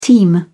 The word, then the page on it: teem: British English